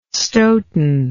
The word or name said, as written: Stoughton